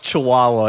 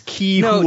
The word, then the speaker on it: Chihuahua: Yes, it's a parenthetical that's how to pronounce Chihuahua in case you thought it was Ki-hua-hua.